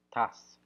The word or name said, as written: tas